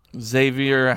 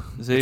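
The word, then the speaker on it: Xavien: Xavier Howard. – Yeah. – How about you say his name correctly? – Xavier. It's not an R at the end, it's an N.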